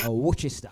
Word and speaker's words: Worcester: This one out to Dan over there in Worcester, Worcester. Get the right pronunciation on that one.